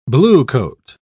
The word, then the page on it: bluecoat: British English